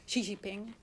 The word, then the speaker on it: Xi Jinping: De president van China, Xi Jinping.